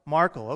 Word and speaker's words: Markle: Markle, okay.